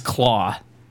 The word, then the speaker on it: Klaue: Ulysses Claw. Claw is spelled K-L-A-U-E. – Ulysses, yeah. Ulysses. What a name. – K-L-A-U-E.